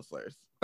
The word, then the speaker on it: Slurrz: And if you want to send Rachel some money, it's her Venmo is T-E-R-R-A-H-C-A-R-D. – No, it's not. It's The Rachel Slurrz. – But it's spelled like that, so. – No, it's not. It's spelled T-H-E-R-A-C-H-E-L-S-L-U-R-R-Z.